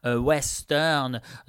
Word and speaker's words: Ouesteurne: Ça s'écrit O-U-E-S-T-E-U-R-N-E, Western.